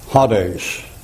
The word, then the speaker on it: Hades: When you take that and transliterate it, you get Hades. And that's a long A in there. Hades. Like that.